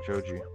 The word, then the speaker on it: Joji: Joji. – How do you spell that? – J J-O-J-I. – It's like JoJo, but with that O.